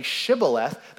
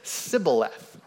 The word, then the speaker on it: Shibboleth: Instead of saying Shibboleth, they said Sibboleth, right? There's a difference there. You can hear it.